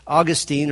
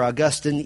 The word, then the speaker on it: Augustine: The opponent's name was Augustine or Augustine. Either pronunciation is correct.